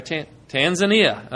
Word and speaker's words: Tanzania: You go back, you go now to southwest Tanzania. Am I saying that right? Or Tanzania, okay.